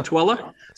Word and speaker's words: Tooele: Hey, that's impressive that you know how to pronounce Tooele, because for those of at home, it's T-O-O-E-L-E. So write that down and try to pronounce it, T-O-O-E-L-E. You know if you've got Utah credentials if you pronounce Tooele right.